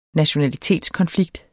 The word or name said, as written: nationalitetskonflikt